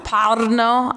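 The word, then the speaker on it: Brno: Pruno. How do you say Pruno? – Parno.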